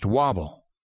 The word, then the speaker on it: WABL: It was the debut season for the Albuquerque Rattlers and their parent league, the Western America Basketball League, or WABL, which the rags in the newspaper pronounced Wobble.